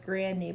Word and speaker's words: Grande: No, I saw a video of her saying that her name is actually pronounced Grandy, but everyone just says Grande. – Pronounced Grandy? – I swear to God. – Ariana Grande? – I know, but everyone says Grande because it flows better, and she just doesn't want to correct anyone.